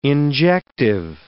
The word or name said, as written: injective